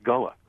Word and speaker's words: Gullah: Yeah, and I also know that it's pronounced Gula.